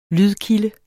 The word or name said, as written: lydkilde